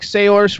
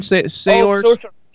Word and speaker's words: Saoirse: It's like Saoirse Ronan or something. Saoirse.